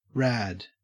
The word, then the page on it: rad: Australian English